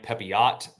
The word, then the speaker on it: Pepiot: You might want to say Ryan Pepiot, but it's Ryan Pepiot. That is how you say it.